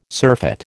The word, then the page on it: surfeit: American English